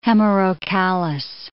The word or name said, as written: hemerocallis